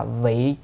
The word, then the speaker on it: vị: Mà bổn nguyện này là vị chúng sanh cố. Chữ này đọc là vị nha, vị chứ không phải vi nha.